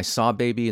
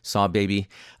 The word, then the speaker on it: Sahbabii: Barnacles by Sawbaby, and that's spelled S-A-H-B-A-B-I-I, Sawbaby.